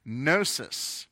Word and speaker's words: gnosis: To use a technical word for this, you have to use the Greek word gnosis. It's kind of like gnocchi, if you like Italian pasta, because it's spelled G-N-O-S-I-S, gnosis, which is the word for knowledge in the Greek language.